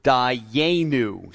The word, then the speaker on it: Dayenu: And this Hebrew word is pronounced Dayenu.